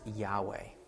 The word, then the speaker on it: Yahweh: Well, most scholars believe the pronunciation of God's name is Yahweh.